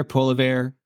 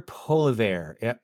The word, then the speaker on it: Poilievre: Do you know, have you watched, I think I'm going to pronounce this incorrectly, Pierre Poilievre, that he's running against Trudeau right now? Dude, get on YouTube, check this guy out. And then it's like Pierre Poilievre. It's not spelled like it sounds, but he's a French-Canadian guy.